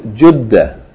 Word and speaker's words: جدة: Yeah, some people call it Jeddah. Some people call it Jeddah. But the proper pronunciation is Jeddah.